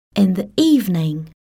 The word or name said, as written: in the evening